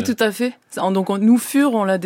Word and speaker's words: Fur: Vous avez pris comme nom de groupe Fur, ça s'écrit F-U-R. Si c'était anglo-saxon, ça serait une fourrure, et ça serait fur.